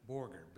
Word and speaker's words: Borger: How do you pronounce it? – Borger. – Borger. – B-O-R-G-E-R. Tip of the Panhandle.